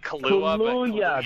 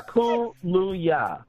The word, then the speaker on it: Kaluuya: It's like Kaluuya. – That's it. Kaluuya. – Yeah, I said it right. – It's like Kahlua, but yes. With a Y.